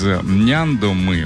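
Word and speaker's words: Няндома: От всей души поздравляю свою любимую мамочку Исакову Людмилу из Няндомы. Ударение на первый слог.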